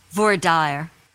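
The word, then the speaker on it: voir dire: How do you say the second um, term there, Joyce? Voidire.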